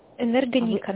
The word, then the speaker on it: Энергоника: Энергоника на «и» ударение. – А, ударение не туда, извините.